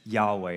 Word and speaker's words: Yahweh: But since then, it has been understood to be pronounced as Yahweh. And so I wrote it there, Yahweh.